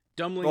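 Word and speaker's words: Doemling: Yeah, I feel it's one of those stupid names where the D-O-E is a D-O-E-M is pronounced dumb. – Dumbling.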